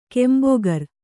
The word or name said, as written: ಕೆಂಬೊಗರ್